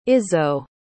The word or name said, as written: izso